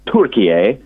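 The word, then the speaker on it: Türkiye: or I should say that I was asked to call it not Turkey but Turkiye, which is the correct pronunciation.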